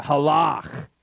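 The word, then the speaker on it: halach (הלך): If you want to say it right, you got to kind of get that guttural thing in your throat. Halach. And halach, or to walk, can mean literally to walk, to ambulate, to move your body forward.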